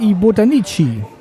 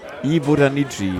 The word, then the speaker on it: I Botanici: Die Jungs sehen geil, das heißt I Botanici. – I Botanici. – Klingt wie die Botaniker.